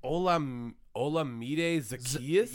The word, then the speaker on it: Olamide Zaccheaus: Olamide Zaccheaus? – Yep, you just nailed it. Yeah, that's exactly how you pronounce it.